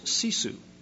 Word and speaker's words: sisu: So the word is sisu, S-I-S-U, S-I-S-U. Sisu is the way I would pronounce it. Maybe they would have a different pronunciation or whatever.